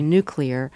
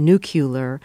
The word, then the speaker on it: nuclear: Now there's a word, if you look at the spelling, it's obviously nuclear. There's no extra U in there as in nuclear. But a lot of people do say nuclear now.